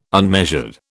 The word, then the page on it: unmeasured: British English